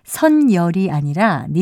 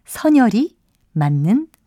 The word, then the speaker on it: 선열: 따라서 선열이 아니라 니은을 첨가하지 않은 선열이 맞는 발음입니다.